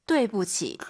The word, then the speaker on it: 对不起: 아이유 사이에 오 그리고 유 아이 사이에는 에 뚜이 부치가 아니라 对不起.